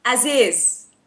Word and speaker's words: Aziz: Aziz.